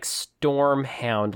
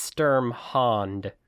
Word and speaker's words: Sturmhound: It does sound like Stormhound. Like, the first couple of times I read it, I thought that actually might be what it said. But no, it's Sturmhound.